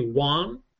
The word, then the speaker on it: guan: Now, the black wan is, it's spelled G-U-A-N, but I think they say wan. Like I think, guan.